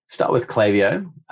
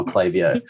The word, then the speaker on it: Klaviyo: Let's start with Klaviyo.